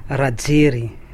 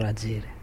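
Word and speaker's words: Rajery: Rajer, on dit Rajeri ou Rajer, on ne sait pas bien, les i se prononcent à peine, non? – Rajeri, on prononce tout court Rajeri. – Mais on me dit qu'on efface un petit peu le y, on me dit qu'il faut à peine le prononcer. – Oui, donc à Madagascar, les derniers syllabes, c'est presque muet, quoi.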